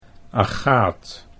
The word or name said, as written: Agaath